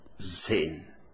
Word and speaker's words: Zen: When it reached to Japan, Rinchai and other masters pronounced it as Zen.